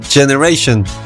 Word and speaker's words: Gener8ion: Alors, ça s'écrit G-E-N-E-R 8 I-O-N, le 8 devenant 8, on a donc Generate Ion. À prononcer, tu l'as compris, t'es pas que idiot, Generation.